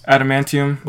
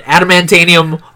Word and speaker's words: adamantium: I said it right. Come on. – Yeah, Justin said it right, and Albert said it incorrectly. – Listen here, I don't have time. – Okay, you're just making vowels. Him mad. He was already mad. Now he's getting even angrier. The more he laughs, the angrier he gets. Like, that's just look. I've been pronouncing it adamantanium all these years.